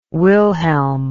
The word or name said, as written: wilhelm